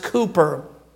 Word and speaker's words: Cowper: It was William Cowper, pronounced Cooper.